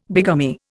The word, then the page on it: bigamy: Indian